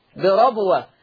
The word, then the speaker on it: بربوة: أيضا لفظ بربوة أصابها، حفص يفتح الراء على فكرة، يقول بربوة.